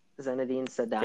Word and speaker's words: Zinedine Zidane: Zinedine Zidane.